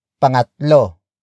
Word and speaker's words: pangatlo: The third exception is pangatlo.